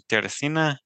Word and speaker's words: Teresina: Brazil, Teresina. Yeah. Did I say it right? – Yeah, Brazil had 2 more temples, one in Teresina, one in Natal.